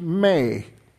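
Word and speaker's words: may (μή): In the Greek text, there is a word, it's pronounced "may."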